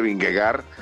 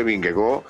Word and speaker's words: Vingegaard: Si usted dice en una transmisión en español, dice Vingegaard, está correcto. Y si dice Vingegaard en el idioma original de él, es correcto. Las dos cosas, las dos situaciones son correctas.